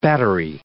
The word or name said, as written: battery